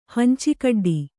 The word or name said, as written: ಹಂಚಿಕಡ್ಡಿ